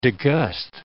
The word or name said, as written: degust